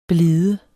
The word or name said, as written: blide